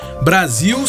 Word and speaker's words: Brasil: Então, por que que apesar do som de Z, como a senhora acabou de falar, Brasil se escreve com S e não com o próprio Z?